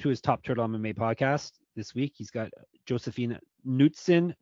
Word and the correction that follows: Knutsson: Nutson, excuse me. It's pronounced Nutson.